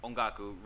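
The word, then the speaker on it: Ongaku (音楽): So on my right arm, in my inner bicep, I have music, ongaku, written in kanji. – Ongaku, ongaku. That's how you say it.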